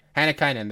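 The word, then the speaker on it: Hannikainen: It's like Hanikainen? Hanikainen. I think that's how you say it. It's a finish. Hanikainen, there you go.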